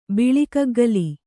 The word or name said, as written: ಬಿಳಿಗುಗ್ಗಲ